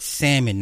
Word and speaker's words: salmon: It's salmon, not salmon. For all you country motherfuckers be like, it's salmon. No, nigga, it's salmon. The L is silent.